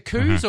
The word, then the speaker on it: coup: It's a silent P, the coup.